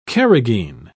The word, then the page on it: carrageen: British English